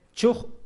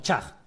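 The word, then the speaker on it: Chugg: Y la llamativa es lo que dice este historiador que se llama Andrew Michael Chug o Chag, depende si queremos hacer la pronunciación anglosajona.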